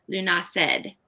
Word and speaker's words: Lughnasadh: August Eve and Garland Sunday, and of course, Lunasa or Lunased. I think some people call it Lunased in some places. I saw that as one pronunciation.